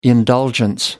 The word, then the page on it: indulgence: American English